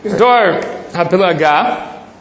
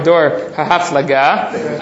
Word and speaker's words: Dor HaPilaga: Dor HaPilaga, it's either got to be, the proper pronunciation is either Dor HaPilaga or Dor HaHaflaga.